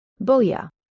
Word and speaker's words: boja: Boja.